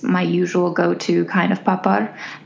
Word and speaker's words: papad: And maybe we should play a sound clip of how it's properly pronounced, but I believe it's something more like papar. Okay. And you kind of turn the second syllable into a rolling UR sound.